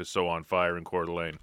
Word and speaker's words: Coeur d'Alene: For example, get this, where the men are meeting in Coeur d'Alene. Did I say it right that time? – You did.